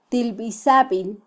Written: tilbisabil

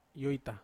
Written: oita

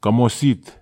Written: kamo sit

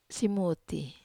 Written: simuti

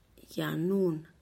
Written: yanun

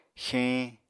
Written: xeeñ

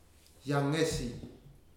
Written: Yagesi

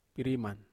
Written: pariman

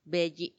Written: beyi

